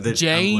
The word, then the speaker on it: Janne: Well, that might be in real life, but in World Heroes, it is J-A-N-N-E. – Oh, okay. Jane.